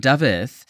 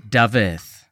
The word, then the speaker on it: Dafydd: He moved over here, and Dafydd uh, is the Welsh version of David, but um, the F is pronounced like a V, and the double D's are pronounced as a T.